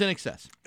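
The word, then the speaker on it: INXS: And In Excess in Rock 102. – Inks. – No, it's In Excess. – Oh, back in the 80s, when they were popular, they were known as Inks in some places.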